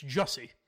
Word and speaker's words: Jussie: It's not Jesse, it's Jussie.